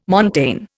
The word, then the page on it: montane: Indian